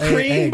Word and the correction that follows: Lucerne: by the way. – Lucrine? – Lucerne. – Lucerne? That's how it's spelled. Lucerne.